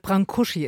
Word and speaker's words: Brâncuși: Brâncuși ist die richtige Aussprache.